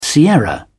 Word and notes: sierra: British English

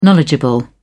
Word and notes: knowledgeable: British English

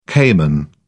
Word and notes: caiman: British English

